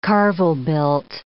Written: carvel-built

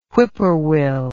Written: whippoorwill

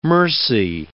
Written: mercy

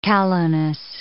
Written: callowness

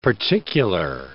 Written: particular

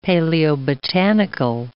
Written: paleobotanical

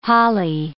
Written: holly

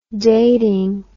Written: dating

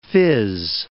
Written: phiz

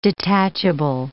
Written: detachable

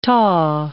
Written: tau

